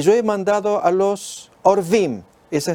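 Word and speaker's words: Orvim (עורבים): Pueden pronunciarse Orvim y significan cuervos. Cuando se pronuncia Arvim, significan árabes.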